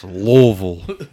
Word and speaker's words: Louisville: That's Louisville. You got to say it like a hillbilly.